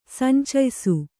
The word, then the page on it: ಸಂಚಯ್ಸು: Kannada